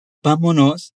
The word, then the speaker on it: vámonos: Note que há um acento em ba, então a pronúncia mais forte se encontra ali. Me chame para ir com você na padaria. Vámonos.